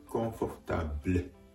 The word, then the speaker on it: confortable: confortable, confortable です。